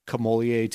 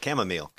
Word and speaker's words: chamomile: Is that just as good of a sleep as if you went to bed after a bubble bath and some, like, camellia tea, or however you pronounce that? – Chamomile.